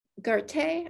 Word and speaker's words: Goethe: One of my favorite quotes um, Garte, I believe is how you pronounce his name, G O E T H E, he said, if the world would just sweep and clean its own doorstep, the world would be clean.